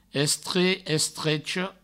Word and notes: estrech, estrecha: Provençal; estre, estrecho

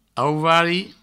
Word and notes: auvari: Provençal

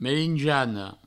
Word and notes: merinjana: merinjano

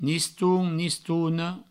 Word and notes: niston, nistona: nistoun, nistouno

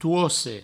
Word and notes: toce: touosse

